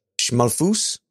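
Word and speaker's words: Schmalfuß: That's a Ringel S. That's pronounced as two S's. – Oh, Schmalfus? – Mm-hmm.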